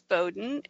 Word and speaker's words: Bowden: My last name is Bowden, and it's two syllables. It's Bow, like what little girls wear in their hair, and Den, like where a bear lives. So it's B-O-W-D-E-N.